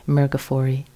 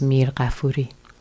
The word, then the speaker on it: Mirghafori: So it's the Americanized version of it is Mirghafori, but the Persian pronunciation is Mirghafuri. I don't suggest you try that, but Mirghafori works.